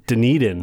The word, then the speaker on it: Dunedin: Dunedin.